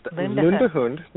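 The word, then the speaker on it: Lundehund: Well, did I pronounce, is it Lundhund? – Lundhund. It's pronounced Lundhund.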